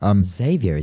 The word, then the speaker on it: Xavier: So final question here from Javier. Well, we've got questions from him before, but every time I get the name wrong. – Xavier, isn't it? – Yeah, exactly.